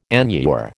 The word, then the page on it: enure: American English